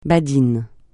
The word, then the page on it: badin: francais